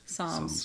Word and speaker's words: Psalms: Them Palms. – Palms. – Psalms. – Psalms. – The P is silent.